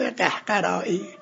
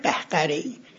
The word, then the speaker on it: قهقری: غالبا غلط تلفظ میکنم، میگن استصحاب قهقرائی، نه باید بگم استصحاب قهقری.